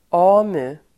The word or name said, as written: AMU